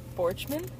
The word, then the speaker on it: Borchmann: Is it just like Borchmann? – That's the American way to pronounce it.